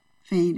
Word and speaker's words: Faine: Fain is spelled F-A-I-N-E.